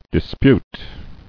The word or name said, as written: Disputable